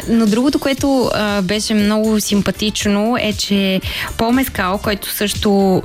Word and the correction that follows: Мескал: Пол Мескал, както е правил. правилното ударение. Пол Мескъл беше на премиерата на Urchin на Харис Дикинсън.